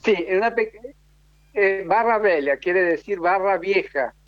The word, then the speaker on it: Velha: A ver, espera que voy a Barra Velha, déjame anotar.